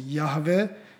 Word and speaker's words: Jahve: Nikdo s jistotou nezná pravou výslovnost YHWH, Protože ve starověké hebrejštině se nepsaly samohlásky, nicméně výslovnost Jahve je zřejmě správná.